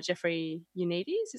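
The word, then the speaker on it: Jeffrey Eugenides: And I know that the author of the film, Jeffrey Unides, is that how you pronounce it? – Yeah.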